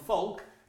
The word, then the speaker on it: folk: And folk. It might look like that, but it's silent L, it's folk.